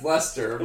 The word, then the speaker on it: Leicester: I think it's pronounced Lester.